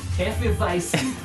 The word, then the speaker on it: Hefeweizen: How do you pronounce it? – Hefeweizen. – we'll go with that. We'll go with that. We'll go with the German pronunciation on that one.